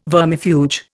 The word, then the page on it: vermifuge: Indian